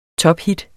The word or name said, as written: tophit